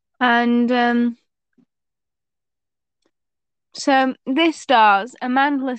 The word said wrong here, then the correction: Amandla: Stenberg. I feel like that's not the right way to pronounce it, but Who? – Amanda. – Amandla.